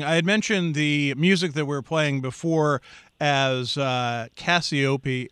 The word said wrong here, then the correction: Cassiopeia: which I mispronounced. It is spelled Casio, you know, like the keyboard that David probably had as a kid growing up. Right, David? Casio? – Yeah. – Yeah, you still do? Yeah, good for you. – Yes. – And then P-E-A, as in the small round vegetable. But it's Cassiopeia is how it is correctly pronounced, Cassiopeia.